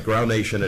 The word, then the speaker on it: Groundation: It's called Dadawa, Peace and Love, and it features four songs that are part of a Rastafarian Grunation? Groundation. How do you pronounce it? Grunation. And Groundation. All right, Groundation it is.